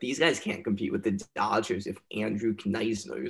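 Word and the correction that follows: Knizner: is their starting catcher. I know Kisner. – I pronounced that wrong.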